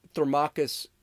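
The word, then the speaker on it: Thrasymachus: Uh, so Thermachus, that's how you pronounce it.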